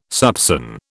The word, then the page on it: soupÇon: British English